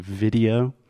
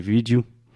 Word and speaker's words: video: This is another word that I just hear Brazilians almost just use Portuguese pronunciation and say vídeo. But in English, we say vid-e-o. – Video. – So first we have a hard D sound, vid, and then we really exaggerate the O at the end.